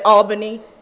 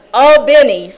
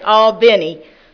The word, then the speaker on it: Albany: Albany, I had to learn how to say that correctly. If I said Albany, I said it wrong again? Oh, okay. Albany, sorry. Okay, Albany. I'm a northern girl.